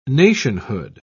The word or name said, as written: nationhood